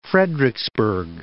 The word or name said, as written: Fredericksburg